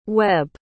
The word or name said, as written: webb